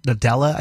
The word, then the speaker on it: Nadella: Nadella I think is how you say the last name Satya Nadella mm-hmm.